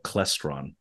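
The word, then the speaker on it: Klaestron: So Claystron. – Yeah, but Claystron. I'm from Georgia. – You're from Georgia? – I'm from Georgia. – Well, North Carolina Claystron.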